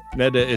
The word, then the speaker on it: Netta: Netta, it's pronounced like cheddar.